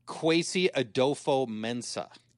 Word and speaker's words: Kwesi Adofo-Mensah: Kwesi Adofo-Mensah.